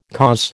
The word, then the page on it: cause: American English